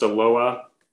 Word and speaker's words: Aloha: Actually spelled like Aloha, but it's pronounced Aloha.